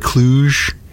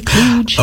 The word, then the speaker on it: kludge: You search a hard G like kludge? – It says kludge, like a J, kludge. – Oh, with a J? – Yeah.